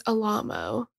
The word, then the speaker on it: Alamo: And I wonder if he, I feel like he wanted it pronounced Alamo just to like be like, it's Frankenstein. – Yeah. – Yeah. No, it's Alamo.